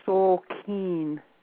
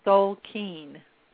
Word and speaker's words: Tzolkin: So seeing pages like Learning Lab and Info Center and MyInsights, Insights, Daily Tzolkin, is that Zolkeen? Is that the pr- correct? – It, it, yeah, pronounced Zolkeen. Soul keen. – Yes. – That sounds kind of like good words in English, soul and keen.